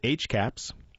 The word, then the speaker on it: HCAHPS: The survey is called the Hospital Care Quality Information from the Consumer Perspective, but goes by the acronym HCAHPS, colloquially pronounced HCAPS, and hospitals are taking this survey seriously.